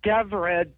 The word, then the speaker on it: Devra: Yes, it's Devra, D-E-V-R-A. I always tell people, say Devra.